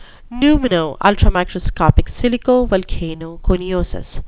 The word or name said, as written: pneumonoultramicroscopicsilicovolcanoconiosis